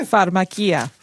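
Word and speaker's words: pharmakeia: I'm going to ask to pronounce it properly for the first time. How does it go? – Pharmakia. – Pharmakia. The Greek word for sorcery is pharmakia.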